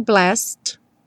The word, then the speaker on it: blessed: E o blessed vai direto também, do S direto para o D, blessed.